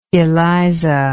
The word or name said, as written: eliza